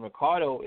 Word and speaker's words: Recardo: You can definitely follow me at my IG Instagram, and that is Rickie Recardo Photography. And Recardo is spelled R-E-C-A-R-D-O, Rickie, R-I-C-K-I-E.